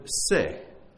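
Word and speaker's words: se (שה): is the word se. You would spell it S-E-H, se.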